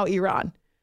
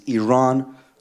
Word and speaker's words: Iran: You say you support the Iranian people, but you're pronouncing our country's name wrong. Our country is named Iran not Iran.